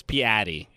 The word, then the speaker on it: Piatti: It's Piatti. That's what he says. It's bad. I don't like it. – It's so different from Piatti. – Yeah. – And that's how you pronounce it his language, in the language that it originated from, in Italian.